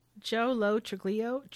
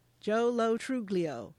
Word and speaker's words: Joe Lo Truglio: Joe Lo Truglio. – Yes, Joe Lo Truglio.